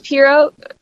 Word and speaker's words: Perriot: Uh, it's pronounced Piro, is um, the proper pronunciation.